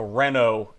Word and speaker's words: Renault: So basically, what happened was in 2008, we had the Renault F1 team, which is a French team. – Everybody knows them. – It's spelled with a T at the end, but you don't pronounce it.